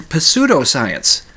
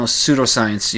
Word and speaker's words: pseudoscience: See, I pronounced the P because I was doing an alliteration thing. If you write it out, it looks okay. You know, all the, you know, the P's in front of all those words. But, you know, pseudoscience, you don't pronounce the P.